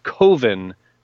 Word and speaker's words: Coven: pronounced Coven because Coven sounds like oven.